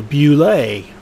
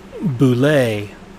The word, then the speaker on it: bulette: Way back when, we called it the Boule instead of Bulette, which is the correct pronunciation from Tim Kask, who invented it.